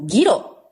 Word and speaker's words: ギロ: まずはですね、ギロ。まあ、 GIRO と書いてギロなんですけど、それと素ブラキ。